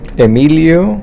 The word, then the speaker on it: Emilio: Emilio, E-M-I-L-I-O, Zamora, Z-A-M-O-R-A.